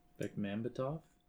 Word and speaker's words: Bekmambetov: Bekmambetov? – Yeah.